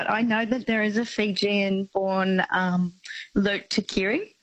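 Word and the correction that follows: Lote Tuqiri: It's pronounced Lottie Tekiri, but yes.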